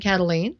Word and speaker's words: Kataline: I'm on Facebook at Karen Cataline, and that's spelled K-A-T-A-L-I-N-E, the last name. So it's Karen Cataline.